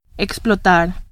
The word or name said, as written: explotar